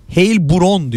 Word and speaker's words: Heilbronn: Heilbronn diye okunuyor. Heilbronn. Hani LeBron gibi değil, Bronn evet. Gibi okunuyormuş. Heilbronn.